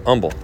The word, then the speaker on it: Humble: In fact, I'm saying Humble because that's how the locals pronounce it, but it's humble.